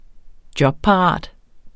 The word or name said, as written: jobparat